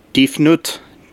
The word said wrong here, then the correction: Tefnut: I think it was Tefnut. – Tefnut, it begins with a D, but maybe it's pronounced with a T.